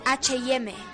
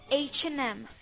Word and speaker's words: H&M: No se dice H&M, se dice H&M.